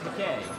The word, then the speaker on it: McKay: Well, that's McKay. – Right. He pronounces it McKay.